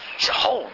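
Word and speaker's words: Sihon: Shihon? Is that how you say it? Shihon? Shihon! That's how they say it in the Hebrew.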